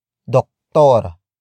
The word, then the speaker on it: doktor: Word for word, it's Doktor. ang, trabaho, ko. Doktor, ang, trabaho, ko.